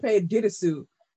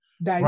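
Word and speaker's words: Dirisu: And I saw one interviewer said Sope Didesu. And I was like, that's not how you say his last name. I was like, that is not, all them accents do not add up to Didesu. Daisu. Right. That's how you say it.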